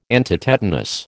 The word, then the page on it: antitetanus: American English